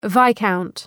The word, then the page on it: viscount: ‘vaı,kaʋnt